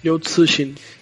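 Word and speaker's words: Liu Cixin (刘慈欣): Liu Cixin. ¿Lo dije bien? – Liu Cixin.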